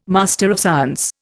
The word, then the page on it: MSC: Indian